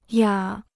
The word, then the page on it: 哑: yǎ